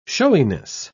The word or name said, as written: showiness